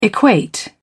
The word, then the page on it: equate: British English